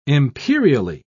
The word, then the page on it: imperially: British English